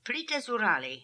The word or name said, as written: Plitazoorali